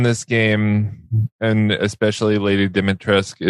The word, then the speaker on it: Dimitrescu: It's Lady D- Dimitrescu.